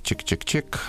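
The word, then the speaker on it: !!!: То есть она так и называется, произносится это как чик-чик-чик, а так печатается как 3 восклицательных знака.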